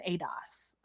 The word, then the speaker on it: ADOS: she's not an ADOS, essentially. Mm-hmm. Right? Did I say that right? ADOS? Yep, you got it.